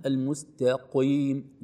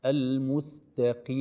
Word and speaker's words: المستقيم: لا نقول المستقيم، لا نعطي القاف حقه كأنه مفتوح أو كأنه مضموم، لا، بل القاف درجات، إذا كانت مفتوحة أعلى، مضمومة أوسط، المكسورة في تكون أدناها، نقول المستقيم. هكذا نطق هذه الكلمة العظيمة في ختام هذه الآية المباركة.